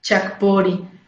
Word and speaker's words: Chakpori: El Facebook es Centro Holístico Chakpori.